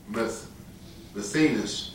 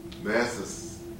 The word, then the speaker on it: Mattathias: But at a little Jewish town called Modin, 17 miles northeast of Jerusalem, there lived a Jewish priest named Mathenas, M-A-T-T-A-T-H-I-A-S. And I didn't pronounce that right. Mathis.